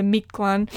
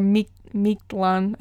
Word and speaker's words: Mictlan: The journey to Mictlan, or Mictlan, I think is how it's pronounced, and how it takes several years for you to get to that land of the dead.